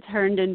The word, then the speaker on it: Herndon: Is that how you pronounce the name? – It's Herndon, but it's okay.